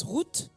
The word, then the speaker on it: Ruth: Le terme Ruth se prononce Ruth.